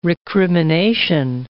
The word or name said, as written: recrimination